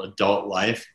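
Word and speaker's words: Adulkt Life: The guitar player from that band is in this band called Adult Life, but it's adult in between the L and the T. There's a K, but you don't pronounce it.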